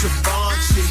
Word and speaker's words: Givenchy: This here is Givenchy.